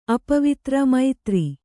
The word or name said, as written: ಅಪವಿತ್ರ ಮೈತ್ರಿ